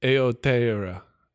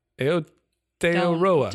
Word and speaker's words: Aotearoa: How do you say that? I'm such a jerk. – Yeah, well, you are. I know that. Aotearoa. Aotearoa.